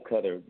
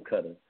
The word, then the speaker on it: KuttaR: it was K-U-T-T-A, but I added the capital R on the end of it, and actually the capital R is silent. Most people say Slim Cutter, but it's Slim Cutter.